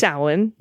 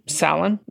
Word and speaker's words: Samhain: I enjoyed hearing a well-pronounced reference to, rhymes with... – Samhain. – Samhain. Yep. Say it again.